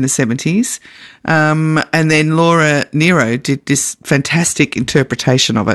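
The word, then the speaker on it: Nyro: Is it Nero doing or Nero? Nero, yeah, actually. N-Y. – Nero. No, Laura Nero, yeah. It's spelt N-Y, so it fools me, but, yeah.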